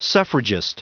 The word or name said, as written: suffragist